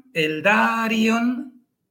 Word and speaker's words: Eldarion: Y lo mismo, anarion, eldarion. Son tienen en este caso cuatro sílabas porque la I y la O están separadas.